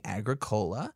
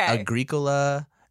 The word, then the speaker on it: Agricola: So there is actually a dispute on this. – Okay. – Agricola and Agricola. I think it's an either situation according to the people I've talked to.